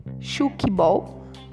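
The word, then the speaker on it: tchoukball: Chukball, tá? Pronuncia chukball.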